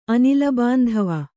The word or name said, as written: ಅನಿಲಬಾಂಧವ